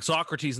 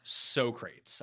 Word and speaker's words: Socrates: Believe that is pronounced Socrates. I heard that in Bill and Ted's Excellent Adventure.